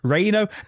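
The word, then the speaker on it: Rhyno: Reino, do it like Jim Ross. Reino. That's that rocket monster, Reino. – Reino. – Like, God damn it, Jim Ross. It is a Y, I suppose.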